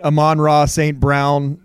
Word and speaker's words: Amon-Ra St. Brown: So they're from the trading of the quarterbacks to drafting Amon St. Ra, who is phenomenal. I don't think I said his name right. Amon-Ra St. Brown. He's got an awesome name that I can never say right.